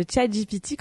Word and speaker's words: ChatGPT: Et voilà, c'est déjà la fin de cet épisode de bilan qui d'ailleurs a été fait en co-création avec ChatGPT enfin ChatGPT, comme dirait ma maman qui m'a suggéré de le prononcer à l'anglaise parce que c'était quand même plus joli qu'à la française.